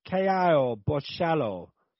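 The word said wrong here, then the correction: Caio Borralho: I can't remember how to say his name. Say his name, Dan. – It's Kai Bohio.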